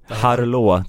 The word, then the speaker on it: Harlaut: Ja, nu var jag dum, men Henrik Harlaut. – Harlå, tror jag. Harlå, att man uttalar det.